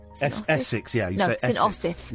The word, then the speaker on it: St Osyth: St Osyth.